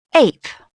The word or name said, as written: ape